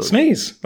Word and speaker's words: SNES: How do you say it? – Sneeze. That's the right way to say it.